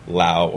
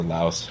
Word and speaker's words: Laos: We did Vietnam, Cambodia, Laos, or Laos, however you want to pronounce it, Thailand, Indonesia, and Singapore.